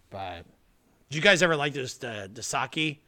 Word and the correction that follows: Dos Equis: Dos Equis? – Dos Equis, yeah.